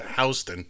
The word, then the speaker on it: Houston: The original city of New York, south of Houston Street, and yes, we pronounce it Houston, although it's spelled Houston.